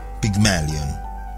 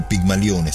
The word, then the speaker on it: Pygmalion: I personaggi che animano la nostra storia sono contenuti nella commedia di Shaw dal titolo Pygmalion, o Pygmalione se preferite. Questo nome lo pronunceremo all'inglese o all'italiana a seconda di come ci verrà più naturale, sapendo in partenza di stuzzicare direttamente il nostro grande autore che di linguistica e di fonetica se ne intendeva molto.